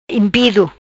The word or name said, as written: Impido